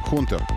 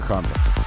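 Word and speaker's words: Hunter: Хунтер. Мы произносим это как Хантер.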